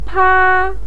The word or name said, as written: pa1